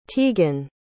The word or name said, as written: tegan